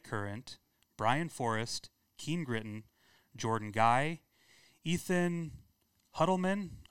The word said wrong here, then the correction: Huttman: I'm sorry if I'm butchering that. – Huttman, there we go.